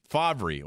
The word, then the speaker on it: Favre: Weird is pronouncing Brett Favre, F-A-V-R-E, as Brett Favre. Yes, it is. It should be Brett Favor or Favre or Fav. I don't know. Never got that one, Favre. I'm still yet to have somebody explain that one enough where I'm comfortable with it. But is anybody really pronouncing it any other way than Favre by now?